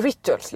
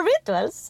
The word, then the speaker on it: Rituals: Nej, men du, vänta, vi måste stanna vid hur du uttalar Rituals. – Är det inte så? Rituals. British. – Tydligt, men också rituals. Ingen P.